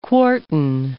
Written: quartan

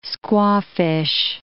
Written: squawfish

